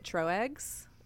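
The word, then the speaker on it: Tröegs: I pronounce it Troegs. It's TRO with the double like dots over it.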